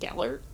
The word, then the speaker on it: Gellert: like obviously like it would have helped them with Galette Grindelwald being there. – Galette. – And Galette? Isn't it Gellert? Oh, I mean I never pronounce the R.